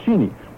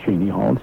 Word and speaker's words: Cheney: But that's not how the family pronounces it. They pronounce it just the same way that the Connecticut Cheneys, as in Cheney Hall, all the Manchester Cheneys. They say Cheney too, and it's not Cheney Hall, it's Cheney Hall.